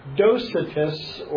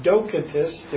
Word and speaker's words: Docetists: There were some called Docetists, or Docetists, depending how you pronounce it.